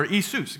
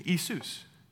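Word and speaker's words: Isus (Ἰησοῦς): And so the challenge though becomes there is no yah sound in Greek. And so it can't be Yeshua. They changed the pronunciation of the name Jesus in Greek to Jesus, or Isus, excuse me, Isus. And there's no yah, there's E, Isus.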